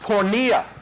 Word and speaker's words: porneia: That word, sexual immorality, in the original language, is pronounced porneia. Porneia. And the word for writing or images is in Greek is graphy.